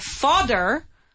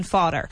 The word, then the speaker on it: fodder: Cannon fodder. So the difference in pronunciation is father, which is padre, father, and then you have fodder, D, fodder, which is el forraje o el...